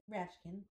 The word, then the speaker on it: Rashkind: Is it Rashkind or Rashkind? I mean, I'm pronouncing it like the American way, I guess, Rashkind. – Yeah, Rashkind.